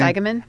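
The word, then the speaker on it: Eigeman: Chris Eigenman.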